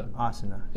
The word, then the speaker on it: Asuna: Asuna.